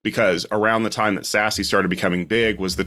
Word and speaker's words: SASE: And because no acronym in IT can be spelled out, it must be pronounced. Someone said, that looks like SASE.